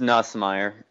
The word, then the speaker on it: Nussmeier: But he was this is how bad Doug Neusmeyer was when he played. – It's Nussmeier. – Nussmeier, of course, I butchered the name. Nussmeier.